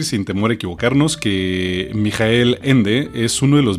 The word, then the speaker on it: Michael: Pues el autor de tamaña obra es nada más y nada menos que eh, Michael Ende, o Michael Ende, ¿no? Digo, si a Michael Schumacher le dicen Michael y se escribe Michael y lo leemos.